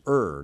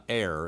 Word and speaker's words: err: And by the way, that is the correct pronunciation. Look it up if you would like. Ergonomics, ergo, etc. It is err, not err, as some people say.